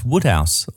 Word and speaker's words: Wodehouse: We've got PG Wodehouse, or I think it's pronounced Woodhouse. Well, that's how I've always pronounced it anyway, but spelt Wode.